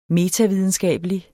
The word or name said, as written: metavidenskabelig